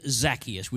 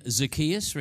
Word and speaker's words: Zacchaeus: People pronounce this name differently. Who calls him Zacchaeus? Would you please raise your hand? Who calls him Zacchaeus? Raise your hand. Okay, that's what I call him too tonight. I'm going to put the emphasis on that syllable and we're all good all night.